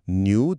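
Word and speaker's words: knew: و یه نکته کوچیک تلفظی، اون که اول نو، نیو و نون خونده نمیشه. نیو دقیقا مثل نیو تلفظ میشه.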